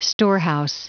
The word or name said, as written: storehouse